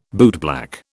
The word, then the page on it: bootblack: British English